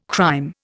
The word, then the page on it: crÊme: Indian